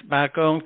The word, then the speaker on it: Bakong: And I can't pass it by without saying he's also the founder of the, how do you pronounce it? Bakong Technical College? Yes, Bakong Technical College, right.